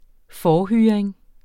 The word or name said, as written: forhyring